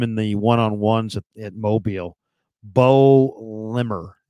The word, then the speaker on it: Baux: His name's Bo Limmer. Mm-hmm. Bo is spelled B-A-U-X, Limmer.